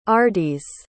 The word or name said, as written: ardys